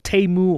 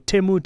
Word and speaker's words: Temu: I'm, of course, referring to new players like Temu or Temu, depending on which pronunciation you use, and as well as Shein and other online retail businesses that import clothes at a very affordable price.